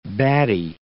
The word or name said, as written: baddie